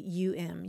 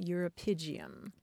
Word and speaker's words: uropygium: Uropygium. Uropygium.